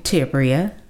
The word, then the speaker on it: Tiberia: Tiberia.